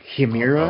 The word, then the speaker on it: Chimera: Well, apparently it's actually pronounced Chimera. Uh-oh. – Yeah. – This is according to Stephen Fry, so. – I'm not there. I mean. – Anyway. – I'm going, yeah. C-H-I in Italian is key, so I'm just doing that.